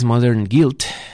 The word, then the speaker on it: Modern Guilt: Esto es Gamma Ray y esto es The Beck, de su disco Modern Girl del año 2006, Modern Guilt, la pronunciación correcta.